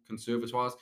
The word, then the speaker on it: conservatoire: Re-auditioning for conservatoires. What? Is it pronounced conservatoire? – Yes. – Right. – It is, it's not like conservatory.